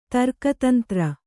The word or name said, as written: ತರ್ಕತಂತ್ರ